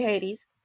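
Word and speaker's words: Haides: Don't spell it Hades the traditional way. It's H-A-I-D-E-S. Still say Hades.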